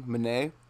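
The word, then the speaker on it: Menne: I might be pronouncing Mene. That wrong. Mene? – Yeah.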